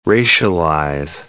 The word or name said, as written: racialize